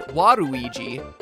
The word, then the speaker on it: Waluigi: Waluigi's name works even better in Japan, where his name is pronounced Waruigi.